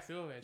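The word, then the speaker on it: Ceruledge: Cerulege? – Yeah, Cerulege.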